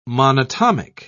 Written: monatomic

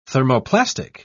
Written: thermoplastic

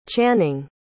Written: channing

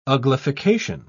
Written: uglification

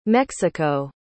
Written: mexico

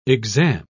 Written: exam

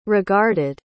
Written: regarded

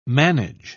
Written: manage

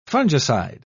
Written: fungicide

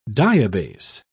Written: diabase

